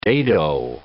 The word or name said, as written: dado